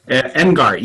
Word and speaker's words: Engar: Engar, E-N-G-A-R.